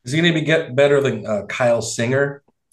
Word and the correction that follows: Singler: Yeah, yeah Kyle got, Singler.